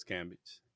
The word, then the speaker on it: Cambage: All right, so the one word of the day, y'all can't see it, but I'm going to put it up here, is Liz Cambage. Is that how you say it? – Yeah, it's Liz Cambage.